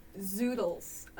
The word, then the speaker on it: zoodles: Her favorite food is gotta be oodles. – What's an oodle? – No. – Is that a real thing? – No. Zoodles. I said it wrong.